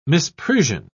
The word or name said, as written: misprision